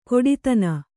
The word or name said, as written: ಖೋಡಿತನ